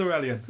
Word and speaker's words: Aurelian: Is it Aurelian? – It is Aurelian. – Yes, Aurelian. – Yeah. Or Aurelian. I don't know, I've always said Aurelian.